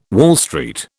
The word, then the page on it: Wall Street: British English